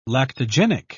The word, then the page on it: lactogenic: British English